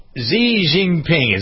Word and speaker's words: Xi Jinping: Xi Jinping, is that how you pronounce his name? – Sounds pretty close.